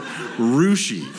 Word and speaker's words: Russia: And there's a town close to it called Rushi, Ohio, that is spelled Russia. And yet everyone calls it Rushi.